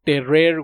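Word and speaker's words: Tarrare: Este eh, es el de Terrer, güey, o sea, era un francés, se llamaba así, güey, Tar, se escribe, obviamente se pronuncia Terrer.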